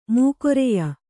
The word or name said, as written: ಮೌಖ್ರ್ಯ